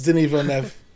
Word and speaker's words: Denis Villeneuve: Yeah, it's Denis Villeneuve.